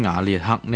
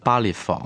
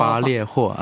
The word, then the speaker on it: 巴列霍: 系啊，关于瓦列克呢个名咧，有位识西班牙文嘅听众咧提醒我哋，其实咧应该读成巴列佛啊，咁即系话黄灿然佢哋以前个译名咧系更贴近西班牙文嘅。 – 系啦，巴列佛就系一个普通话嘅译音嗰、巴列霍啊，咁就会更似佢嗰个西班牙发音。